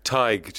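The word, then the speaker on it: Tadhg: Tig. Just take the off the end of Tiger.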